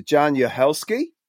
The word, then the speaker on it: Jaan Uhelszki: So, a very warm welcome to Jaan Uhelszki.